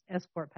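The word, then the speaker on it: ESCRT: that's pronounced escort pathway, This award, the Shaw Prize, is often referred to as the Nobel Prize of Asia.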